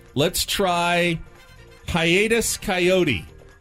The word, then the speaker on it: Kaiyote: I mean, it's the Coyote is spelled K-A-I-Y-O-T-E. – I know it from looking at the list yesterday. – Hiatus Coyote. – I'm unfamiliar with Hiatus Coyote.